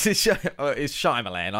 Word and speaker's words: Shyamalan: It's Shyamalan, I know we're talking about.